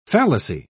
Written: fallacy

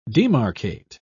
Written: demarcate

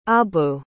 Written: abu

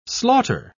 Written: slaughter

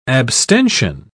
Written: abstention

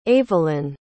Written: avalynn

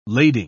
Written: lading

